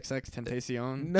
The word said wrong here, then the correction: XXXTentacion: Yeah, I'm pretty sure it's XXXTentacion.